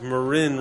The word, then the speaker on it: Marin: And Marin is pronounced Marin, rhymes with tin.